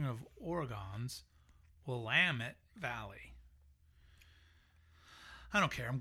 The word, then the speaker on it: Willamette: So this person says, so for the record, the correct pronunciation of Willamette, as it relates to the Oregon wine region, is Willamette. – Willamette. – As in Willamette, damn it.